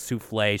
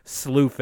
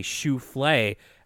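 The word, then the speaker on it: soufflé: sort of short-lived joke around this part where DW doesn't know how to pronounce souffle. She keeps saying like sloofe and souffle. – Sloofy.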